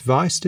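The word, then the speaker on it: Wiest: So this new paper, which is now, again, you're going to have to correct my pronunciation, Tobias Weist, is it? – Oh, I don't have it in front of me. – It's W-I-E-S-T. – Weist.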